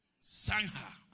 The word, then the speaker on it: zanja: Después hizo una saña, si lo dije bien, zanja, amén, zanja, zanja, zanja.